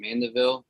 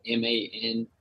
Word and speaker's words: Mandeville: So all my socials are Logan Mandeville all together. My last name is spelled MANDEVILLE.